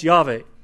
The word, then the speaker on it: Yahweh: Well, again, ancient Hebrew was written in consonants. They had no vowels. So without vowels, they weren't sure how to pronounce the name. So if we were to transliterate it, they just had YHWH. What's that mean? Well, if you don't speak it, then you don't know what it means. If you speak it, then you could look at it and say, oh, that's Yahweh.